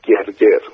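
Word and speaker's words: Gırgır: حالا بخواییم فارسی تلفظ کنیم میشه گرگر، خوشم میگن گرگر، مجله بود بسیار پرطرفدار و خب من به شدت جذب اون شدم.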